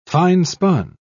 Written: finespun

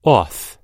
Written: auth

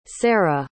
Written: sara

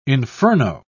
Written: inferno

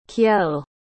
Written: Kjell

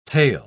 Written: tale

